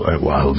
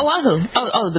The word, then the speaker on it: Oahu: Oahu, good. I never pronounced it right. It's the Big Island. – Yeah, Oahu.